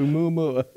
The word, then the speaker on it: Oumuamua: Oumuamua.